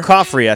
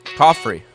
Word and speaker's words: Coffrey: Coffrey, I think. – Coffrey. Like Joffrey, but Coffrey.